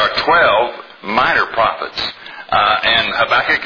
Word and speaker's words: Habakkuk: His name is Habakkuk. It's spelled H-A-B-A-K-K-U-K.